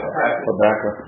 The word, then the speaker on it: Habakkuk: In other words, in Psalms, you'll see the word Selah about 71 times, and then in Habakkuk, how you pronounce that? – Habakkuk. – Mm-hmm.